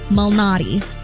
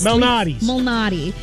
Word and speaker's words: Malnati: In golf, Peter Illuminati? – That's not it. And I was saying it right. It's just now I'm not saying it right. Peter Malnati. – Leads, like the pizza place.